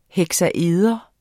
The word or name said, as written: heksaeder